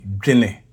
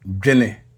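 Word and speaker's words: dwene: If I spell that in English, it would be D W E N E. D W E N E. And it's pronounced jine.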